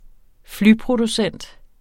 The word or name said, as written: flyproducent